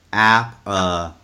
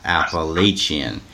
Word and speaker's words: Appalachian: Sam Callen, Bruce, the correct pronunciation of Appalachian State is Appalachian, not Appalachian.